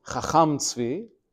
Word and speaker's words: Chacham Tzvi: Although you pronounce his name as a Svardi, Chacham Tzvi, he himself was Ashkenazi, as his last name indicates.